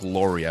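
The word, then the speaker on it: Lore: If Kevin Garnett comes aboard as a minority owner, if Alex Rodriguez, and I believe it's Mark Laurie, I believe it's pronounced.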